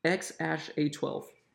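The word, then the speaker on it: X Æ A-12: Okay, Musk told the Joe Rogan Experience podcast Thursday the name is pronounced X-A12. So you still have to call him X-A12.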